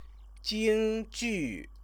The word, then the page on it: jingjiu: Chinese